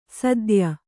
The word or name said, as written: ಸದ್ಯ